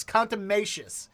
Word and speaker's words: contumacious: So our word of the day today is, here we go, contumacious. Nope, I definitely said that wrong. Let's listen to the pronouncer. – Contumacious. – Yes, contumacious.